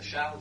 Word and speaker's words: Charles: Ça s'écrit Charles en anglais, on doit prononcer Charles, non ? – Charles. – Charles. Alors, ça s'écrit Charles, comme le prénom Charles en français.